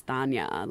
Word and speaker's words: Tanya: or even like my parents pronounce my name as Tanya, like mm. with a mm. soft T, and it, immediately when I went to school, I was like, oh, everyone's calling me Tanya.